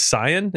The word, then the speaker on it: Cyan: Who's the story that's not paper thin and fucking hits you in the feels is Cyan. Is that how you pronounce his name? C-Y-A-N? – Yeah.